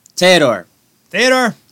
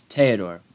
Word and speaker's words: Theodore: Theodore. It's Theodore.